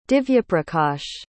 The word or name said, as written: divyaprakash